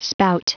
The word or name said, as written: spout